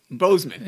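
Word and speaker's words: Boseman: Chatwick Bowman. Bozeman. – Yep. There we go.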